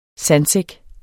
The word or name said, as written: sandsæk